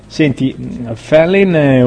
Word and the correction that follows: Fallin: Felin, l'ho detto giusto? – Fallin. – Ah, Fallin, va bene.